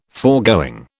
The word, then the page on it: foregoing: British English